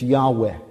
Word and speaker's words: Yahweh: In the Hebrew, it's Yahweh, okay? Yahweh has 2 syllables, but Jehovah has 3, Jehovah, right?